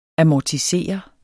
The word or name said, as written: amortisere